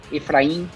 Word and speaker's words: Efraim: E aí eu já vou perguntar sobre esse sobrenome, porque eu já vi duas pronúncias, né? Efraim, Efraim. Como é que a gente pronuncia, Anitta? – Efraim. – Efraim. Efraim, perfeito.